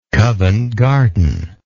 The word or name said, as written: covent garden